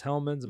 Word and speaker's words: Hellmann's: East of the Rockies, it's Hellmann's.